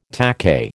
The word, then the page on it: takahe: American English